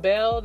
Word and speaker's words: Bell: You can add me as a friend, Jalecia Bell. That's spelled J-A-L-Y-S-S-I-A. Last name, B-E-L-L.